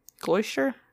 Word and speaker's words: Gloucester: Gloucester? – Gloucester.